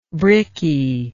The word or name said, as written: bricky